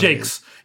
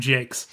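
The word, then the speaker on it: Jacques: Did you know that the Redwall books were written because Brian Jakes or Jocks, I can't remember which one. – Jakes. He was a notorious like Francophobe. – Okay. – And so he pronounced his name Jakes.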